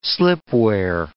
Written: slipware

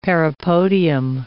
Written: parapodium

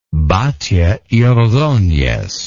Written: batlle y ordonez

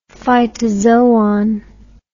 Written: phytozoon

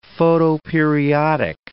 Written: photoperiodic

